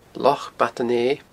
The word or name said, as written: Loch Bad an Fheidh